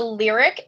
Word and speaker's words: Lyriq: So the car behind you is called the Lyriq IQ, and it's the first Cadillac EV, electric vehicle? – It's called the Lyriq, and it's spelled L-Y-R-I-Q.